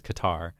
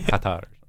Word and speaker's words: Qatar: Sometimes referred to as Qatar. – Oh, yeah. – I think the real pronunciation is somewhere in between those two. Yeah. It's like Qatar.